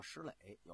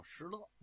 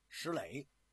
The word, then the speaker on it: 石勒: 说你王传林有时候说叫石磊，有时候叫石勒，你到底叫什么呀？其实这也不是个多音字。过去啊，老先生说这个部书的时候，他就叫啊石磊。后来我查了查呀，这个字啊念勒，勒索的勒。